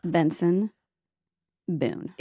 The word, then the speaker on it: Benson Boone: Benson Boone.